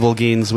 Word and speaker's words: Holguin: In Spanish, the H is silent. – That makes sense, then. – Yeah, so it's Holguin is Holguin is Holguin. So it's a Spanish name? – Yeah, I don't know.